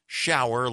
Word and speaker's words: Schauer: March spelled like the month, Schauer like you're taking one.